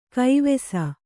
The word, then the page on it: ಗವಿಸು: Kannada